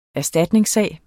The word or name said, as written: erstatningssag